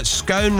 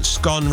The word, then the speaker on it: scone: When I travel back to Newcastle-under-Lyme, where I was born, my current home in Newcastle-upon-Tyne, I have to change back to scone rhymes with cone, away from scone rhymes with gone, because otherwise I'm accused of being posh.